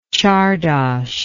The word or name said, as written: csardas